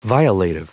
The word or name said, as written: violative